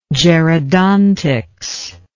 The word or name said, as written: gerodontic